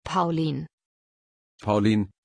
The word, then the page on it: paulien: German